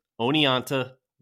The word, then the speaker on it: Oneonta: Now, one last thing, getting back to that pronunciation of whether it's Oneonta or Oneonta. Since Watkins named the gorge for his hometown in New York, I just went ahead and called up the city in question. I talked to a lovely woman at reception who worked for the city council, and she assured me that the town's name is pronounced Oneonta with the T.